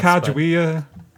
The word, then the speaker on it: Sacagawea: Man, he's like the Inuit Sakagawe, or how you pronounce Sacagawea. I know her name's hard to pronounce. – Sacagawea.